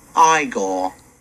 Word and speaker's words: Igor: You must be Igor. – No, it's pronounced Igor.